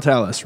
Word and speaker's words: Talos: General Talos rhymes with Dallas.